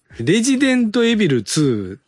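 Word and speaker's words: Resident Evil 2: レジデントエビル 2。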